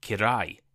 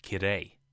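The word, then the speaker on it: kirei (きれい): I said kirai instead of kirei.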